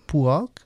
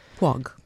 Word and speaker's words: Puig: The instrumentation forgoes the group's usual synthetics instead of placing the spotlight in the violins and violas of Alex Puig. How do you pronounce his last name? Puig? Puig? – Puig. – Puig, okay.